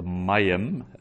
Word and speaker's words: mayim: So, the mayim as well, the accent is on the ma, so penultimate stress.